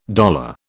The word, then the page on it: dollar: British English